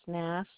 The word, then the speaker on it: Nass: Last name is Nass, and it's spelled N like North, A like Apple, S like Sam, S like Sam.